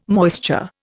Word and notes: moisture: Indian

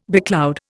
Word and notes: becloud: Indian

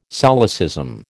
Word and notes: solecism: American English

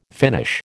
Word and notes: finish: American English

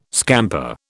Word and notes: scamper: British English

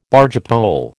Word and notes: bargepole: American English

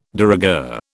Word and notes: de rigueur: British English

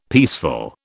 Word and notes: peaceful: British English

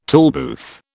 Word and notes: tollbooth: British English

